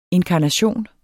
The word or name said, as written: INKARNATIONERNES